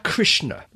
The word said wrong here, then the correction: Kirshner: M-I-A-K-I-R-S-H-N-E-R. – Kirshner. – Sorry. Kirshner.